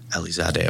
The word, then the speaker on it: Alizadeh: Put a lot of emphasis on the A in the middle, but Alizadeh. – Yeah. – Alizadeh.